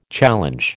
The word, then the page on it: challenge: American English